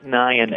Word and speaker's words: Nyhan: Nick Nyhan, N-Y-H-A-N.